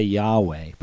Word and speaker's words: Yahweh: And we, in English, we would say, if we were trying to say it as it's spelled, YHWH, we would say Yahweh, but there were no vowels in the Hebrew language.